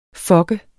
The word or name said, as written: fucke